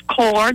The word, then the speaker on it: Corps: And then we have Marine Corps. It's core, the P is silent, people. Please stop saying the P.